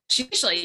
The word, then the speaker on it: vizsla: So yeah, what and Ro is a how do you pronounce it? vizsla? Oh god. – Yeah, vizsla, you got it.